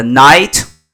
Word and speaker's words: knight: 大家好，从今天呢，我们开始读《Magic Tree House》的第二本《The Night at Dawn》。这个 night 呢，跟晚上 Good Night 这个发音是一样的，但是呢，前面有一个 k。那么有一个 k 情况下呢，它是骑士，中世纪的骑士。At dawn，dawn 是黎明的意思。就是在黎明的时候，可能他们又去了一个地方，然后碰到了一个骑士。好，我们开始读第一章，Chapter One: The Dark Woods.